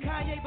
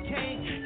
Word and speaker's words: Kanye: All I need is y'all to pronounce my name. Kanye, but some of my plaques, he still say Kane.